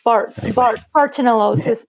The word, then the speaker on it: bartonellosis: Bartonellosis.